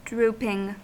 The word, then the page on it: drooping: American English